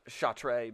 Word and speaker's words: Chartres: Charter House is pretty good, and it's spelled C-A-R-T-R, no. – Apostrophe R. C-A-T-R-E-S. – Chartres. – Chartres, but they pronounce it charter.